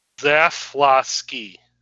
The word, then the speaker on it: Zaslavsky: Can one of you Polacks tell me how I – because I said Zavosky, right? – Zaslavsky. – That's how you say that? – Just like it's spelled.